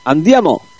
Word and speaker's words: Andiamo: Andiamo.